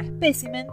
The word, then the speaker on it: espécimen: La forma espécimen es la correcta. Se trata de una palabra esdrújula, pues el acento prosódico recae en la sílaba P y por tanto debe llevar tilde.